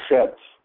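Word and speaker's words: Chet (חֵית): The sound, Za, the eighth letter, is called a Chet. Its pronunciation is guttural, C-H.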